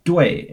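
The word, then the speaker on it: 对: A super common word with this final is DUE in the fourth tone, which is spelled DUI in the fourth tone, DUE, which means correct.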